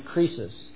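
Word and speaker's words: krisis (κρίσις): It's the word, it's pronounced in the Greek, krisis, but in the English, it's pronounced crisis.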